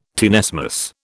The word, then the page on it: tenesmus: British English